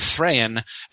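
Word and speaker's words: Thráin: And basically, you know, that is, many people have noticed, for instance, how I keep saying Thrain as the father of Thorin, you know, Thror, Thrain, and Thorin, of course. And the reason that I do this, I think that it is fairly clear that it's supposed to be two syllables.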